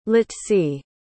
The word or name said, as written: litzy